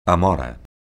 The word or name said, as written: amora